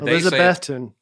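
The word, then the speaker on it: Elizabethton: Elizabethtun.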